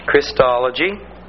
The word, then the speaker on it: Christology: I want you to learn how to pronounce that properly. It's pronounced with a short I, like Christmas. Christology.